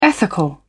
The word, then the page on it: ethical: British English